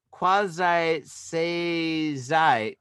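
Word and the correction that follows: Kakusei-zai (覚醒剤): Did I say that right? – Kakusei-zai. – Kakusei-zai.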